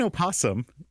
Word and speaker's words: opossum: The O in opossum is spelled but not pronounced. It is a silent letter like the H in honest, according to Wikipedia. According to the Merriam-Webster's dictionary online, it says that... Similar kind of thing. It says that... Possum or opossum can be pronounced with its first syllable either voiced or silent.